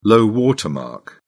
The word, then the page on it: low-water mark: British English